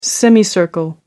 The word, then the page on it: semicircle: American English